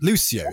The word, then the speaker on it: Lucio: I mean, is it pronounced Lucio? Measure for Measure. – Oh, that's the sort of one. – Because he's funny, he drinks a lot, and he seems like quite... I think he's the funniest Shakespeare character. Lucio is pronounced.